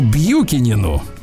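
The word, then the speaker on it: Бьюкенен: Приветствие президенту американскому Бьюкинину. – Вот, э, пишется Буханан.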